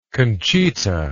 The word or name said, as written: conchita